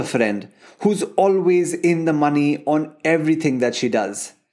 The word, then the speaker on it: Mani: Well, it's money, written as M A N I, but pronounced as M O N E Y.